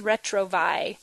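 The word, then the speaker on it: Retrovaille: It looks like it says Retrovail, but you don't pronounce the two L's. So it's Retrovi.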